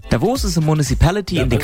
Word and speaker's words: Davos: Davos. – Right. Okay. So Christian has been on to say, we stress the O in Davos.